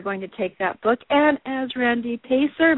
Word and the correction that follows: Peyser: Pizer, P-E-Y-S-E-R.